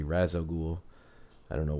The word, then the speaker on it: Ra's al Ghul: So, but I do pronounce it Ra's al Ghul.